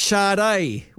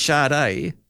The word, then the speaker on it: Sade: Her name is pronounced Sade. Was he really there in the 80s? Yeah, well, goodness. I mean, she was everywhere. Yeah, I love it. Yeah. But very much it was always Sade.